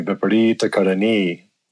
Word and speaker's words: Viparita Karani: There are some other ones really that are pretty glaring, like, Viparita Karani. Everybody says that, where it's actually Viparita Karani. A little bit different.